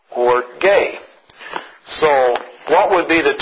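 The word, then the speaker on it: orge (ὀργή): The word is pronounced in Greek, orge. And it sounds a little bit like our English word orgy.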